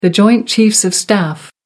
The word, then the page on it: joint chiefs of staff: American English